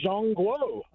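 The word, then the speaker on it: Zhongguo (中国): You know, to the Chinese people, they pronounce their country Zhongguo.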